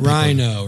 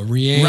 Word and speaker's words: Reino: What are the wrong pronunciations of your name? – That Rhino, Reno.